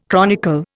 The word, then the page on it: chronicle: Indian